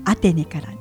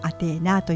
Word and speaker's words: アテーナ: あのアテネから、アテーネっていうのはあの読み方を変えて、はい、ギリシャ語読みがアテーナというそうなんです。